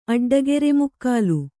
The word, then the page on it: ಅಡ್ಡಗೆರೆಮುಕ್ಕಾಲು: Kannada